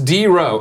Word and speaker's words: DRux: DRux87, pronounced D-Row. Oh, because he's using that EUX shit, like when the saints say go.